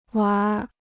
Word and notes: 玩儿: wánr

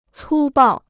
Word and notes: 粗暴: cū bào

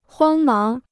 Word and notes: 慌忙: huāng máng

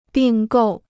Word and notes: 并购: bìng gòu

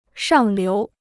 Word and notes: 上流: shàng liú

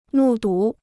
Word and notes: 目睹: mù dǔ

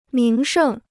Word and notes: 名胜: míng shèng

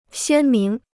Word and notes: 鲜明: xiān míng

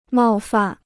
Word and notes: 冒犯: mào fàn

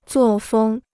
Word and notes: 作风: zuò fēng

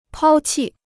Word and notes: 抛弃: pāo qì

